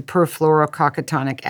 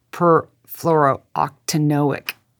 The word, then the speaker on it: perfluorooctanoic: First of all, I'd always gone around with the perfluorococcotonic acid, and I found out how embarrassing for me. I'd been pronouncing it wrong. Perfluorooctanoic.